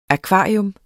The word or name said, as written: akvarium